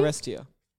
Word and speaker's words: Oresteia: And also the second book is An Ortesia, which is how you say that. It's spelled O-R-E-S-T-E-I-A. So An Ortesia. Anyway. This which is the Orestia? – Orestia, maybe? – Orestia. – Yeah, Orestia.